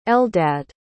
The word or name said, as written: eldad